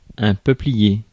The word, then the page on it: peuplier: France (Paris); IPA: [ɛ̃ pœ.pli.je]; French